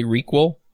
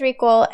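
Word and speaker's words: ReQL: Did you say Requel? – Is that how you pronounce it? I'm not sure. – Yeah. That's exactly how we pronounce it. – Okay, so it is Requel, and then everyone says it feels very functional, so...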